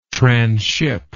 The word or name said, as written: tranship